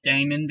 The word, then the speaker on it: daemon: Do you guys pronounce it demon or daemon? – I pronounce it demon because that's how they pronounce it in the audiobook, and the audiobook is narrated by Philip Pullman.